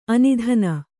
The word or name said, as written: ಆನೆದಿನ್